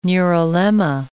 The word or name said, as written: neurilemma